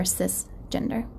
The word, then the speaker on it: cisgender: No, it's actually cis with a C. So oh. It's spelled C-I-S, gender.